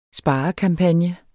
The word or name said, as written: sparekampagne